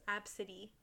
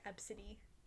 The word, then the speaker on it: Abcde: Oh, a weird one, but, like, it's low-key pretty, is Absidy, but it's A-B-C-D-E. – A-B-C-D-E? – It's written A-B-C-D-E, but you pronounce... Absidy. – You pronounce it Absidy.